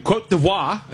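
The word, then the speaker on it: Côte d'Ivoire: I mean, look, Cote d'Ivoire is how you put it, I guess, is the proper way to pronounce it.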